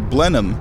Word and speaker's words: Blenheim: Blenheim, spelled like Blenheim. B-L-E-N-H-E-I-M. Blenheim Palace.